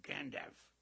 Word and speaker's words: Gandalf: So I said, what would that rhyme with? He said, well, like half a sixpence. Uh-huh. Half. Gandalf. But he pronounced the L. You could hear the L.